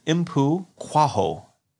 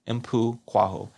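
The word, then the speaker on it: Mpho Koaho: So we have actor Impu Kwaho. That's how you pronounce it. Even though it doesn't look like that. Yeah. So it's Impu Kwaho.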